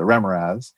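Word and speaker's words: Remorhaz: And so when I talked to Errol, I knew he designed two iconic D&D monsters, the Remoraz, which he corrected me, that's how you say it, the Remoraz,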